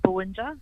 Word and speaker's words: Bowinja: Now, the Bowinja Connection, I hope I'm saying it correctly, you curated it. It features 17 titles, I believe. Can you tell us about this collection? – I believe you are saying it correctly, Bowinja Collection.